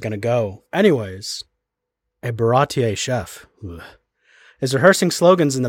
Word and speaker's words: Baratie: I think they still said Barati, but they said it very, like Barati, not Barati. – I watched the Funimation dub. They got real French with it. – Oh. – Is it like Baratier? – Yeah, it's Baratier.